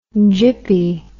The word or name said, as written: gippy